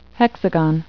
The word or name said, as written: hexagon